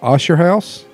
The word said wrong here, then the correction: Akershus: Akershus? – Yes, see?